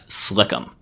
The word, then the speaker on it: SLCM: The NPR also calls for a new nuclear sea-launched cruise missile, or SLCM, which nuclear wonks often pronounce as Slick'em.